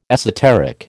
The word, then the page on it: esoteric: American English